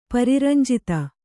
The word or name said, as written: ಪರಿರಂಜಿತ